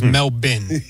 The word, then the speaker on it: Melbourne: Well, I thought it was Melbourne when we started today, but it's Melbourne, okay. And that is courtesy of Noah Eagle. – So Melbourne. The Australian pronunciation, Sal, is Melbourne.